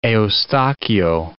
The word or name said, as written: Eustachio